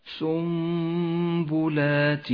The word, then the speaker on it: سنبلات: وأخيرا حكم الإقلاب في كلمة سنبلات، النون الساكنة وبعدها حرف الباء، تقلب النون إلى ميم، لونت النون باللون الرمادي إشارة إلى عدم نطقها، وكتبت فوقها الميم وقد لونت باللون الأخضر لأنها تحتاج إلى غنة زمنها حركتان. تقرأ هكذا سنبلات.